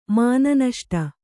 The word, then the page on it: ಮಾನನಷ್ಟ: kannada